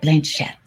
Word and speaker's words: Blanchett: Blanchett. Did I say that right? Blanchett. – Yeah.